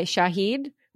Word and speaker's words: Shahid: And you're filming season 2, and it's produced by Shahid, which is an Arabic streaming platform. Did I say that correctly? – That's correct.